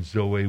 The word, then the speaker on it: zoe: But by experiencing a new birth, a new life form called Zoe life, and I give you the spelling up there and the pronunciation Zoe,